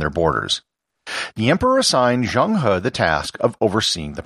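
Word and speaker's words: Zheng He: If you read the title of this episode and you speak English, you might pronounce it as Zhanghe. I'm trying to stay true to the Chinese pronunciation as much as I can.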